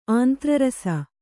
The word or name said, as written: ಆಂತ್ರರಸ